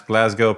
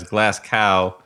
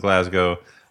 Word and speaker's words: Glasgow: Thanks must go to Elliott, who managed to pronounce Glasgow properly and not as Glass Cow, which many Americans do, to the endless frustration of Glaswegians.